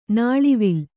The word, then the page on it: ನುಲುವಾಲೆ: kannada